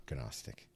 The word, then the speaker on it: Gnostic: It's like Gary Gnu. Yes. It's the news. Yes. Anyways. I don't know, Reinhardt. It's not Gnostic, Jesus.